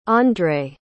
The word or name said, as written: ondre